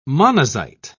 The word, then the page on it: monazite: British English